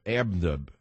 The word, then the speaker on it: Airbnb: Airbnb.